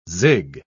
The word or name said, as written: zig